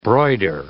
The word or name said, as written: broider